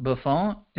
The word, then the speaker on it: Buffon: That, by the way, I've never heard this man's name, this Count Buffon.